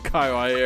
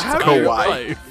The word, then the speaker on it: kawaii: Me, and I went to the kawaii area, and why do you keep saying it that way? – Because up until this very moment, I thought that's how you said it. – No, it's pronounced kawaii.